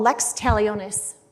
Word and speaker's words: lex talionis: Um, but this principle called lex talionis.